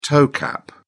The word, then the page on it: toe cap: British English